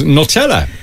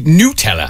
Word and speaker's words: Nutella: Although the one that blew my mind in relation to the mispronunciation of words was Nutella, which is actually Nutella.